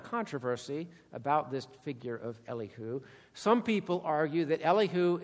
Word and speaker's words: Elihu: I'm going to call him Elihu. Some people pronounce his name Elihu. Choose. You can pronounce it either way.